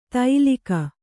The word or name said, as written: ತೈಲಿಕ